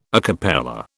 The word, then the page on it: a cappella: British English